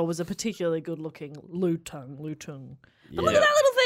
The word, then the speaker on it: lutung: How would you say that? L-U-T-U-N-G. – L-U-T. – Yeah, lutung.